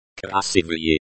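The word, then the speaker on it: krasivye (красивые): The stress in chasu. Chasy is on the second syllable. The stress in krasivye.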